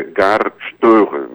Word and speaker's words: Støre: cujo líder é Gahr Støre, naquela pronúncia dos ozinhos atravessados do norueguês.